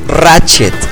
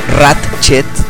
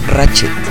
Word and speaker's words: Ratchet: Eh, Optimus Prime, eh, Jazz, Starscream, Ironhide, Ratchet, porque es Ratchet, no es Ratchet, es Ratchet. La T de medio, del medio no, se pronuncia.